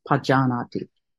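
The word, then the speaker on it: pajānāti: Stilling the Kaya Sankara, Pajanati, walking, you know, the body postures, walking, standing, it's all Pajanati. I'm emphasizing the pa just so you hear it, but it's a short A, so it's Pajanati.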